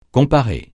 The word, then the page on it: comparer: francais